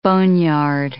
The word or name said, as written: boneyard